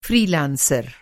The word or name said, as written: फ़्री लांसर